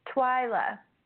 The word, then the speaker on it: Twyla: Twyla.